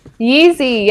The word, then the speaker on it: Yeezy: Yeezy, yeah.